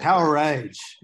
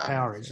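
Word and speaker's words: Powerage: Power Age, actually. – Power Age. – Yeah, it's yeah. not Power Age. It's Power Age.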